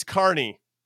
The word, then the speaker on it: Kearny: It's Kearney, New Jersey, and I appreciate you guys for not calling me out, but I know that you can tell that I'm not from that area because I didn't pronounce it correctly.